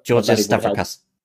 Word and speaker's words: Georges Stavrakas: Sorry, do you want to try that again? – No, I don't want to. No, you try it. You try it. If you're not fine with my pronunciation, you can try this. I am the person that is not even a native English speaker here. – Okay, Georges Stavrakas. – Okay, sure. I will accept that.